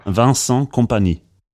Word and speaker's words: Vincent Kompany: Vincent Kompany.